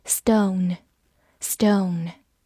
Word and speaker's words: stone: 字母 o 发它本身的音 o，字母 n 发一个鼻音，嗯，末尾的 e 不发音。 stone，stone，它是一个名词，表示石头或者宝石。